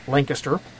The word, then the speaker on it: Lancaster: It's called Save Against Fear, held in September in Lancaster, pronounced Lancaster, Pennsylvania.